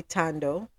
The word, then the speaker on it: Ntando: N-T-A-N-D-O. That's probably Tando. The N is silent. So that would be Tando.